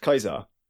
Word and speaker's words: Caesar: This is the really weird thing, is that over time and over our own pronunciation, we now pronounce it Caesar. But technically speaking, it should be pronounced Kaiser. – Oh, Latin doesn't have a soft C. So the word Caesar could literally not have existed. – But we do. It's where we get the word Kaiser from in German. – Yeah, Kaiser. – Yeah, I figured that. – It comes from Kaiser, which is the correct pronunciation of Caesar.